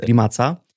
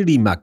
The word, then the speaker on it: Rimac: Rimac czy Rimac? Słuchajcie, właśnie, ustalmy to na początku. Tak. Rimac czy Rimac? – Jest powszechnie uznane, zaakceptowalne mówienie Rimac, ale w Chorwacji czyta się to nazwisko Rimac.